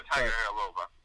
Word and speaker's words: Tagovailoa: Can you guys pronounce that? Tua? – Tagovailoa. – Okay. I thought it was a lot longer than that. – Tagovailoa.